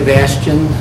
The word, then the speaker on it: Bastian: My name is Stanley Bastian. That's S-T-A-N-L-E-Y. Last name B-A-S-T-I-A-N.